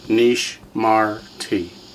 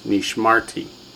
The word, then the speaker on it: Nishmarti: How many syllables? Nishmarti. Three full syllables. Nishmarti.